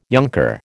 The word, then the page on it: younker: American English